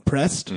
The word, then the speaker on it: Pressed: it starts with a P and it rhymes with stressed and it's called pressed, mm-hmm, they have nutritional effects on it.